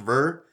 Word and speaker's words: Bheur: that it's pronounced Ver. It's spelled B-H-E-U-R. – Yeah, I want to call it the Ber hag because it is based in the frozen tundra and frozen mountains.